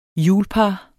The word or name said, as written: hjulpar